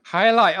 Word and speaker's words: jai alai: I must not pronounce it right. J is for J-A-I-A-L-A-I. – Hialeah. – Oh, okay. J is for Hialeah.